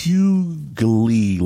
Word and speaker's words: Hughley: It's Hughley, like so. – Hughley. – Yeah, when you spell it, it's huge, L-E-Y, Hughley.